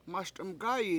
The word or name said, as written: MASd1m gA7i